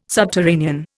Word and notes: subterranean: Indian

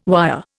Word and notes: wire: Indian

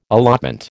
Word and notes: allotment: American English

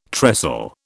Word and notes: trestle: British English

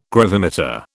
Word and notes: gravimeter: British English

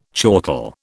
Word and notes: chortle: British English

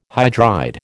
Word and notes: hydride: American English

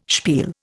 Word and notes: spiel: Indian

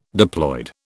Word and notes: diploid: British English

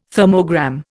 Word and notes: thermogram: Indian

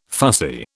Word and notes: fussy: British English

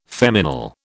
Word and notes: feminal: British English

